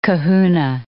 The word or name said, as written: kahuna